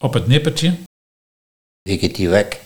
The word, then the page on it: Wikkertie wak: Nieuw lekkerlands